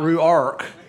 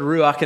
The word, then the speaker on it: Ruach: But it is the beginning of our new series called Ruach, not Ruark. You know, that's the southern way to say it, but it's not the correct pronunciation of it, Pedro.